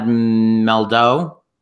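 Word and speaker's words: Mehldau: I'm gonna butcher his last name. Brad Meldo. Is that correct? – Mm-hmm. I think so.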